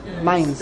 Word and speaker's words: Mainz: Mainz.